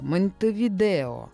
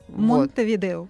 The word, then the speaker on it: Монтевидео: Чтобы все выучили, как правильно звучит столица этого государства. Монтевидео. – Да. Монтевидео. – Вот. – Именно так стоит говорить правильно.